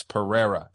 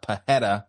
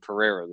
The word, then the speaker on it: Pereira: But if it's in the middle of their name, it's an R, like Alex Pereira. It's not Pajeta, like a lot of fucking frauds.